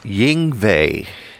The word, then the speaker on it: Yngwie: Yngwie Malmsteen. – Yes. How do you say that again? – Yngwie.